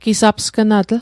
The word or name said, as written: gisapsgnatl